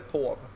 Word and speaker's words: torv: Det är bara att de uttalar det tov.